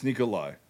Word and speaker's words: Nikolaj: It's Nikolai. It's just the fucking J is a Y.